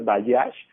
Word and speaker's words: Bajaj: Bayash. Se escribe Bajaj, las dos con J,